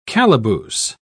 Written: calaboose

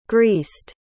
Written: greased

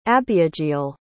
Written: abiageal

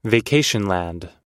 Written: vacationland

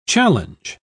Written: challenge

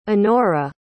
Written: annorah

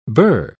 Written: bur

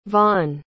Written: vahn

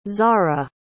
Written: xara

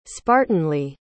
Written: spartanly

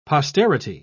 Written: posterity

